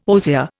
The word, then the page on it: osier: Indian